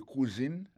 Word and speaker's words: cuisine: Ou dans le cuisine là-dedans, c'est comme ça, on dit cuisine, on prononce cuisine en anglais. C-U-I-S-I-N-E. C'est cuisine. Alors que,